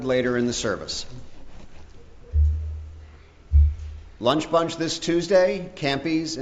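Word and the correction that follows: Champy's: in Smyrna. Am I saying that right? Champies in Smyrna? – Champies. Mm-hmm.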